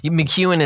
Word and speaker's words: McKeown: McEwen is how you pronounce it, but yeah, you wouldn't know by just looking at the name.